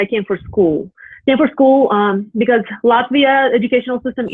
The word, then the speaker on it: Latvia: She is from Latvia. Did I pronounce that correctly? – Yes, you did.